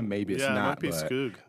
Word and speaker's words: Skoog: So for me, when I see that Ed, I think about like Ed Skoog. How do you say Ed's last name? S-G-O-O. – Skoog. – S-K-O-O-G.